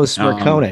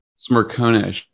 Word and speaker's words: Smerconish: Smirnik Smirnika. He's a CNN guy. Oh, Smerconish, um, sure. – Smerconish, yeah.